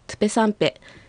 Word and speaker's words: トゥペサンペ: はい、 8 個はトゥペサンペ、 9 個はシネペサンペと発音されます。